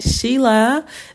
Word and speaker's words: Sheela: You guys can find me on Facebook at Sheila Bela, spelled S-H-E-E-L-A,